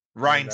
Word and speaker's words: Rhyn: But yeah, so we go to the net to another match and it's the Pizza Party versus the Knights of Rhyn. I don't know what Rhyn sounds right. Cause it looks like Rhyme with an N.